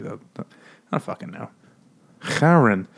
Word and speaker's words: Charon: Charon.